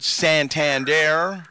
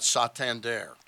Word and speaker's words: Santander: I've heard Santander. – Right. – Which is more emphasis on the sand at the beginning of it.